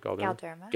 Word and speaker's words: Galderma: She's a national trainer both privately and as a Galderma. Is that how I said that right? – Galderma.